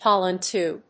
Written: pollen tube

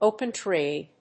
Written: open trade

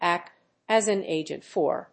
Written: act as an agent for

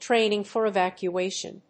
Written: training for evacuation

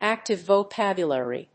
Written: active vocabulary

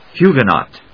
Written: huguenot

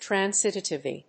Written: transitivity

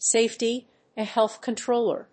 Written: safety and health controller

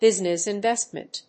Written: business investment

